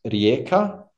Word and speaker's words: Rijeka: it's called Rijeka, and it's a good club, solid first division club.